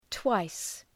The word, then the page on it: twice: twaıs